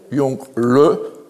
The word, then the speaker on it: Yongle: Alors je rappelle, en chinois, le E se prononce E muet. Yongle, en chinois.